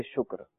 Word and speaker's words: شکر: شکر، کاف جو ہے نا یہ مجزوم ہے، یعنی کاف پر کوئی حرکت نہیں ہے، ٹھیک ہے، شین پہ پیش ہے،